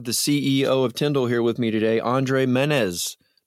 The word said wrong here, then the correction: Menezes: No, I didn't say it right. I just blew it. Menez. It's the extra S at the end. Menezes. Well, listen, it's still an impressive kind of almost shocking statement to hear just this enormous growth that you're experiencing.